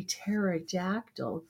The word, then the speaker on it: Pterodactyl: Why can't you hear a pterodactyl going to the bathroom? All right, somebody's got to get this. Because the P is silent.